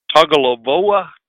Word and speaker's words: Tagovailoa: Tagovailoa? – Tua Tagovailoa.